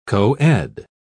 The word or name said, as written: coed